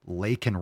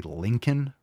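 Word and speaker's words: Laken: But the cringe thing was, obviously, Biden, if you watched clips or something from the State of the Union, he called Lakin Riley Lincoln Riley. Said it wrong. The teleprompters were wrong. Like, he said it wrong. He said, you know, the closed caption on the screen just said Lincoln Riley, not Lakin. It's a big difference.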